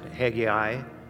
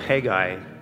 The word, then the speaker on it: Haggai: Now, there's different ways you can pronounce that. Some pronounce it Haggai, some pronounce it Haggai. I choose the one with the less syllables because it's better for me. So we're going to go with Haggai today, okay?